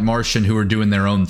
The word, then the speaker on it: Marcion: It wasn't until probably Marcion, as we pronounce it, it's actually Martian is the correct pronunciation,